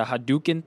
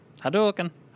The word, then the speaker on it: Hadouken: Which is better, the you know, posing the Hadouken thing? – Yes. – Or the toothpick? – Well, is it pronounced Hadouken. – Hadouken.